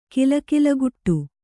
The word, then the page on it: ಕಲಕಲಗುಟ್ಟು: kalakalaguṭṭu